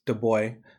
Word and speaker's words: Du Bois: We can speak French. It's 2020. It's Du Bois. – Unless you're from Du Bois, PA, which I learned Du Bois, PA does not pronounce it Du Bois, Pennsylvania, but they call it Du Bois.